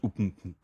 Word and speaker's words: UPnP: Es wird UPnP ausgesprochen.